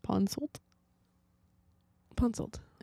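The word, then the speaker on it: Ponsoldt: No, the T would be silent, wouldn't it? Because it'd be. Pon Sold. Yep. Okay, so I said it right.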